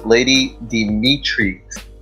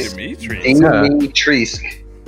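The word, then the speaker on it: Dimitrescu: And through my massive Googling, I have figured out that officially it is Lady Dimitrisk. Weird, right? Dimitrisk. The U at the end is silent.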